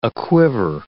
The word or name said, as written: aquiver